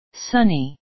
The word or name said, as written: sunny